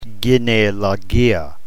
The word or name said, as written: genealogia